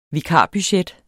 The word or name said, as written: vikarbudget